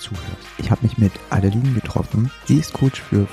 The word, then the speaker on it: Adeline: Und mein Vorname ist französisch Adeline. Das mhm. ist die richtige Aussprache.